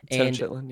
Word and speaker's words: Tenochtitlan: He thinks it's the city of Tenochtitlan. I believe that's how you say it in Mesoamerica. – Tenochtitlan, yeah.